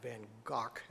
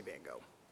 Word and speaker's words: Van Gogh: Vincent van Gogh, or actually his name's pronounced Van Gogh, as in Locke, but I'm going to say Van Gogh.